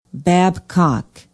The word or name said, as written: babcock